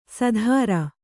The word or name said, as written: ಸೂದ್ರ